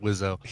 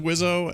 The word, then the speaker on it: Wiseau: I guess Randy told us it's pronounced Wizzo. – He says he prefers Wizzo, and so dry humping Lisa's stomach.